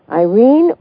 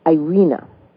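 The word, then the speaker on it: Irena: Irene was 98 years old when she died. I should say it's not Irene, it's Irina, or maybe it would be pronounced Irina.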